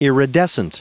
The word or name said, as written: iridescent